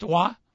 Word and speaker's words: Roy: It was spelled R-O-Y? – Mm-hmm. – Pronounced Waugh? – Mm-hmm.